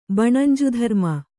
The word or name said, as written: ಬಣಂಜುಧರ್ಮ